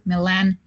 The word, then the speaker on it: Millan: Well, to connect with me where I'm most active right now, Instagram, Twitter, and YouTube, all of them the same username, Carolina Millan. Millan is spelled with double L, Milan, double L.